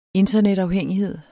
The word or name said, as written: internetafhængighed